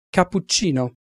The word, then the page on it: cappuccino: Italian